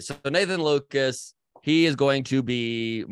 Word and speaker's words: Nathan Lukes: So to start with, and we both know this and we both have messed it up either way, but his name is pronounced Nathan Lucas, even though it is spelled Lukes.